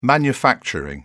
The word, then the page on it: manufacturing: British English